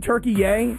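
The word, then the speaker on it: Türkiye: Listen, I liked saying Turkey Yay because I found this interesting that there was this new pronunciation of the country Turkey.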